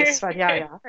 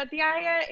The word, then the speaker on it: Svadhyaya: How did I do on that pronunciation? – That was great. – Okay, Svadhyaya. – Perfect. Yes, yeah, Svadhyaya, it's literally sva plus adhyaya.